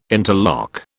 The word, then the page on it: interlock: British English